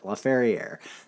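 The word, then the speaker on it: Laferrière: Ferriere, because there's an E at the end of it, and when there's an E after it, you pronounce the consonant. Laferriere.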